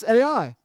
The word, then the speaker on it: Ai: That is actually how you pronounce it. It's not I must say, the first time I read it like a South African. I went, "I." But it's Ai.